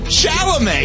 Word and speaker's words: Chalamet: By the way, is Chalamet too much? Is Timothy Chalamet? What's his name? Chalamet? – No, Chalamet.